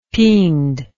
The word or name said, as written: piend